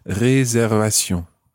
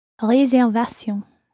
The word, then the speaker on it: réservation: And it looks exactly like the English word reservation with the exception of the E acute at the beginning. Réservation. – Réservation. – But it's a good example of a word that you must pronounce in French, even though it looks like English.